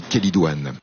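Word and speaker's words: chélidoine: Ça s'écrit Chélidouane, mais je crois qu'on dit Kélidouane.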